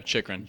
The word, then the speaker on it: Chychrun: Yeah, Chychrun, Jacob Chychrun from Arizona.